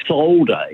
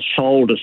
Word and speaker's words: solder: Another one I've noticed a lot is we say solder, if we're going to solder something together, whereas the Americans say solder. – There's no L.